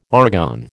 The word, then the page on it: argon: American English